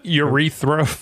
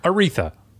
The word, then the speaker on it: Aretha: Urethra. – Urethra. – This comes from Urethra Franklin. – Aretha. Sorry, wait. How do you say that name? – Aretha.